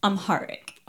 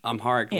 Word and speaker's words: Amharic: I was looking over and listening to it, and you have the whole song, I Am, that's sung in, how do you pronounce the language? Is it Amharic? – Amharic. – Amharic, yeah. Language.